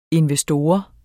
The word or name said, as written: INVESTORERNE